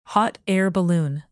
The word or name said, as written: hot air balloon